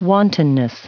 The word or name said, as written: wantonness